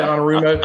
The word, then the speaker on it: Anarumo: Yeah, and I think this year was since they brought in defensive coordinator Lou Anarumo, and how you say it, Anarumo? – I think that's right.